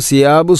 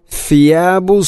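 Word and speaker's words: ഫിയാബു (ثياب): ആലിയഹും സിയാബു സുന്ദുസിൻ എന്ന് പറയുമ്പോൾ അത് തെറ്റാവും, കാരണം ഫാഇൻ്റെ മഹ്റജ് അതിൻ്റെ ഉച്ചാരണം പൂർണ്ണമായിട്ടും മാറുന്നു. അപ്പോൾ ഫിയാബു സുന്ദുസിൻ, ഫ ഉച്ചരിക്കുമ്പോൾ നാവിൻ്റെ അറ്റം മുൻപല്ലുകളുടെ ഇരനിരകൾക്കിടയിലൂടെ അല്പം പുറത്തോട്ട് വരണം. ശേഷം ഖുദ്റുൻ, ഇവിടെ സ്വാദിന് പുള്ളിട്ട ദോദാണ്.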